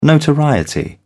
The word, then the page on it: notoriety: British English